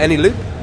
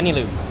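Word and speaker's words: Eneloop: AnyLoop? – Yeah, AnyLoop.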